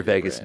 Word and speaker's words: vagus: That's your Vegas nerve, buddy. – Interesting. – Yeah. – Like pronounced like Las Vegas? – Pronounced like Las Vegas, but it's V-A-G-A-S. – Vegas, I see, V-A-G-U-S. – Oh, it's not, I thought it was V-A-G-A. Anyway, yeah. – Yeah, V-A-G-U-S is a modular of the brain-gut axis.